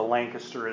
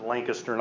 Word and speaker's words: Lancaster: Like there's a Lancaster in Pennsylvania, and they pronounce it Lancaster, not Lancaster. If you go there, please remember that. They get very cranky.